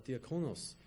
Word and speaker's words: diakonos: And so in the original Greek, it's pronounced as diakonos, but we kind of translate that to deacons.